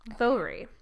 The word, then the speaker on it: Voirrey: Her name is spelled V-O-I-R-R-E-Y. Vori.